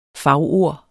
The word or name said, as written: fagord